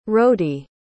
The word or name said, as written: rhodey